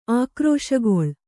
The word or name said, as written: ಆಕ್ರೋಶಗೊಳ್